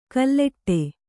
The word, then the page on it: ಕಲ್ಲೆಟ್ಟೆ: kalleṭṭe